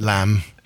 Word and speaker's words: lamb: I know about that lamb. Yeah. The weird thing about that word is it has a B at the end in English, but it's pronounced the same as the German. Lamb.